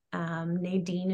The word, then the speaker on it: Nadine: Nadine is spelled Nadine.